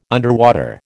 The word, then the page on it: underwater: American English